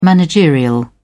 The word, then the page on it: managerial: British English